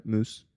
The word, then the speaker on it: Meuse: In France, right? Meuse? – Mm-hmm. – Yeah. – That's pronounced correctly.